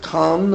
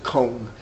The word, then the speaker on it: Kahn: Now, you can pronounce it Kahn or you can pronounce it Kohn.